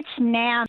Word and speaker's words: NAMM: How was your weekend last weekend at the, is it pronounced NAMM? NAMM? – It's NAMM.